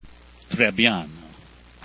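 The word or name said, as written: Trebbiano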